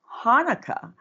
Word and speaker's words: Chanukah: But what's Chanukah? I'm like, It's pronounced Hanukkah.